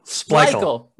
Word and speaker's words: Splichal: Splichal.